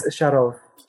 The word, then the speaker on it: Sharov: And it's Vladimir Sharov, right? Not Sharov. – It's Sharov. – Sharov. – Sharov. – Okay. – Sharov. The stress is on the second syllable.